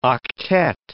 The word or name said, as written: octet